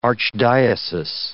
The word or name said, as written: archdiocese